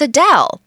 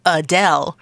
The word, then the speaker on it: Adele: On a show recently, the musician explained that most people say her name as Adele. But actually, she says it's Adele.